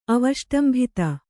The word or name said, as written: ಅವಷ್ಟಂಭಿತ